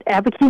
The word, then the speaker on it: Abiquiu: Abiquiu, New Mexico. Am I saying that right? – That's right. Abiquiu, New Mexico.